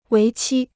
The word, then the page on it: 为期: wéi qī